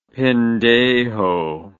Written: pendejo

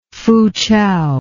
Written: Foochow

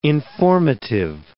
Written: informative

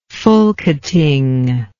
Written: Folketing

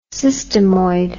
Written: systemoid

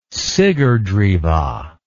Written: sigrdrifa

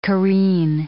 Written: careen

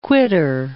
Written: quittor

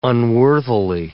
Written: unworthily

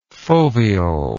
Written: foveole

